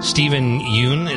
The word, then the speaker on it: Steven Yeun: But Steven Yeun, is that how you say his name? – Yep.